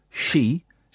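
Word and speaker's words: she (四): In Japan, the number 4, she, sounds like the word for death. She.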